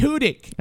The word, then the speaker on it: Tudyk: It's Tudyk.